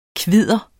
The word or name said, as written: kvidder